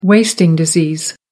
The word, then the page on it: wasting disease: American English